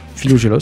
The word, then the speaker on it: Philogélos: D'ailleurs, c'est un petit recueil qui s'appelle L'ami du rire, qui s'appelle le Philogélos. Je ne sais pas si je le dis bien, Philogélos.